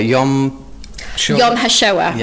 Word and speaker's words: Yom HaShoah: You're also part of, I'll let you do to pronounce this properly, in Manchester, Yom HaShoah, yeah, in Manchester.